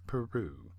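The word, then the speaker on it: Peyroux: And then we ended the set with the beautiful vocal stylings of Madeline Peru. Not spelled like the country. No. It's P-E-Y-R-O-U-X. Peru.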